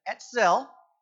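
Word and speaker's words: etzel (עצל): For instance, the Hebrew word A-T-S-E-L, etzel, is how I would pronounce it.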